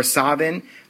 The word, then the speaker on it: Samhain: I like the reference to what should be pronounced Samhain or Savin, but which most of us are going to pronounce Samhain. Michael broke into the school at one point in time and wrote Samhain on the wall.